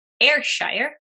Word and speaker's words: Ayrshire: And then I also see Brown Swiss, Milking Shorthorn, Red and White Holstein, and Ayrshire. That's probably not how we pronounce it. – No, that is. That's right. – Okay, excellent.